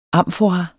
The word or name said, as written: AMFORAS